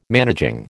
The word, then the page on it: managing: American English